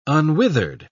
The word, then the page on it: unwithered: British English